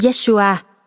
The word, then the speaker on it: Yeshua: Pamela Rigby và Jesus Tôi gặp Giêsu là tên gọi của Chúa Giêsu bằng tiếng Do Thái cổ, phát âm trong tiếng Anh như chữ Yeshua khi tôi 33 tuổi.